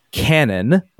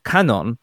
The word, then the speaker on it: Canon: Canon is not like English. Canon, like a shooting it out of canon, but something close to Kanon with the no slightly nasalized and again very slight delay before the N sound.